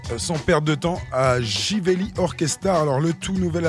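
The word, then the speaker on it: Živeli: Alors Jivelli, ça se prononce Jivelli, mais ça s'écrit avec un Z, Z-I-V-E-L-I.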